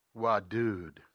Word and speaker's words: wadud: For example, wadud, ah-u, the ah-u sound with the emphasis on the second syllable means it penetrates to the very deepest place, the deepest place of heart.